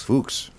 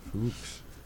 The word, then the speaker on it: Fuchs: Fuchs. – Fuchs. – Whoa. – F-U-C-H-S.